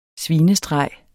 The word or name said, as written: SVINESTREGEN